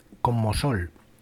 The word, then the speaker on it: Como Sol: Hemos escuchado el tema que me parece que antes lo he dicho mal, se titula Como Sol.